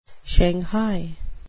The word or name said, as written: shanghai